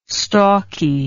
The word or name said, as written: stalky